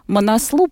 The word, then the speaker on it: Манаслу: Манаслу правильно произносить. Видите, насколько я далека от альпинизма? Манаслу, да. Даже неправильно произнесла название вершины.